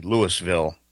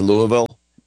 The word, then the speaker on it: Louisville: And I believe it's pronounced Louisville, but Louisville? – What did I say? Louisville? – Louisville. – Louisville. That's how it's Louisville.